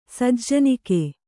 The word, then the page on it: ಸಜ್ಜನಿಕೆ: kannada